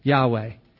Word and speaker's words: Yahweh: Some people have pronounced it Jehovah, which is a really strange pronunciation. But the word, I was actually talking to a friend of mine, a Messianic Jewish rabbi friend. I said, do you mean Yahweh? Is it pronounced Yahweh? And of course, being unable to pronounce it himself and unwilling to pronounce it himself because it's such a holy name, he said, that's really close.